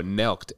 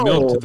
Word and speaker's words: nelked: No, nelked. N-E-L-K-E-D. – Oh, nelked. – Oh, I thought it was milked this whole time.